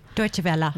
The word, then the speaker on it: Deutsche Welle: I'm so excited, and I want to congratulate on her recent acceptance into a Deutsche Wells program in, or Deutsche Welle. I think that's the right way to say it? – Deutsche Welle.